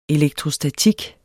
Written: elektrostatik

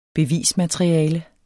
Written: bevismateriale

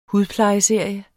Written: hudplejeserie

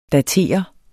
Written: datere

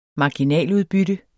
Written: marginaludbytte

